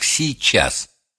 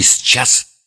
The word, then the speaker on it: сейчас: Произносите слово «сейчас» именно как «сейчас», а не «счас» и не «сейчас».